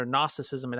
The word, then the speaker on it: Gnosticism: For the listener, Gnosticism, it actually has that silent G.